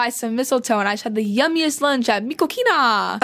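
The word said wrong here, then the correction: Mi Cocina: Because he didn't know how to pronounce Mikosina.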